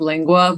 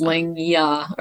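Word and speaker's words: Langya: I always say it wrong, like tongue in Spanish, like lingua, but it's lingya or something.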